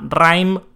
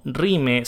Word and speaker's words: RiME: Se llama Rhyme o Rime, si lo quieren pronunciar mal.